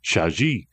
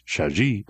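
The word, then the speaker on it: Chagee: And to resist a tendency to mispronounce Shaji, the G is soft.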